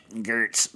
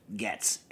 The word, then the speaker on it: Goetz: For those of you that have never been to the Northeast or Pennsylvania or anything, Getz is spelled G-O-E-T-Z, so it should be pronounced Gertz, but it's Getz. And it says on the package, because they're sick of people mispronouncing the name, Getz.